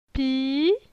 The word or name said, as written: Pi